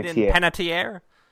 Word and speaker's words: Panettiere: Hayden Panettiere, how do you say it? – Hayden Panettiere.